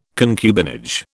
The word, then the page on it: concubinage: British English